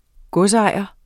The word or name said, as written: godsejer